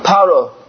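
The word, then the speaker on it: Paro (פרעה): In Hebrew, how do you say Pharaoh? Paro. Paro is the letters Pei, Resh, Ayin, Hey.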